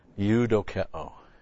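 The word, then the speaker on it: Eudokeo (εὐδοκέω): Eudokeo. Now, E-U-D-O-K-E, long O.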